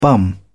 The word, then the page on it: bum: British English